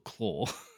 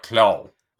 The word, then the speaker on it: Klaue: Well, yeah, because it's spelt K-L-A-U-E. – Well, yeah, but it's still Claw. – Yeah, but it's more like Claw.